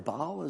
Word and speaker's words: Baal: And that's who the Zidonians were worshipping along with Baal, or Baal as some people pronounce it.